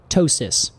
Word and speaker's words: ptosis: Oh yeah, and then the P, silent P, ptosis, which means to fall off.